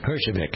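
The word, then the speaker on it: Herjavec: And there still are people who are doing that, as is evidenced by yesterday we had Robert, I never can pronounce his name right. – Hershevik mm-hmm.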